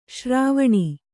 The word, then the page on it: ಶರವಣ: Kannada